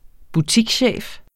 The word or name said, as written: butikschef